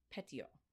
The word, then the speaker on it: Petiot: So this guy's name is, in English we would say Marcel, but since he is French, it is Marcel Petiot. Okay? Got it? – That was really bad. – Can you say Marcel Petiot? – Marcel Petiot.